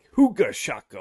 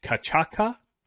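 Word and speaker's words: cachaça: I was thinking more like huga chaka, huga chaka. But it's cachaca, cachaca.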